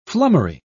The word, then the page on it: flummery: British English